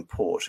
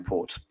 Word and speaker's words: Porte: And in 1805, reluctantly, the Ottoman Sublime Porte, again, Sublime Porte, port, literally the French iteration of gate, where the edicts of the Ottoman Sultan are pronounced.